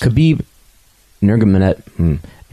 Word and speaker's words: Nurmagomedov: Khabib Nurmagomedov. – Nurmagomedov.